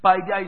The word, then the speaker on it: paideia: Paideia is spelled P-A-I-D-E-I-A.